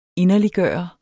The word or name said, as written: inderliggøre